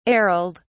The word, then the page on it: ariled: British English